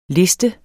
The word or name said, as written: liste